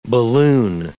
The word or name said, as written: balloon